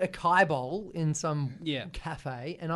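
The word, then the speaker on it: açaí: A-C-A-I. I'm sure I'm not even pronouncing it right. – I think it's acacia. – Acacia? No, there's acai. Only one C.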